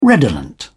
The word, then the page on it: redolent: British English